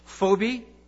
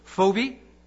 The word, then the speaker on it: Phoebe: And although the Apostle Paul respected women and worked side by side with them for the furtherance of the gospel, you have Phoebe, that's the Greek pronunciation, really how it should be translated in the English, Phoebe, in Romans chapter 16, verse 1.